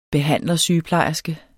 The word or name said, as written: behandlersygeplejerske